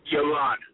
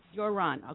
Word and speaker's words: Yaron: Yaron. – Yaron, okay.